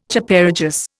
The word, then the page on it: Chaparejos: Indian